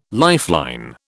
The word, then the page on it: lifeline: British English